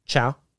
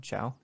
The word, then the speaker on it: Chau: C-H-A-U. How do you pronounce that? C-H-A-U. Chow. – C-H-A-U. Chow. Yeah.